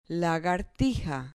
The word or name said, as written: lagartija